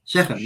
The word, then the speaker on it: Shechem: And his brothers went to graze their father's flock at Shechem. – Shechem, yeah. – Oh, I got it right. Okay. At Shechem.